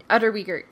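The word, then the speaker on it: Unterweger: I think it's Utterweger. He was Utterweger. – Utterweger, right. He was a strangler that murdered 3 prostitutes during his stay at the hotel.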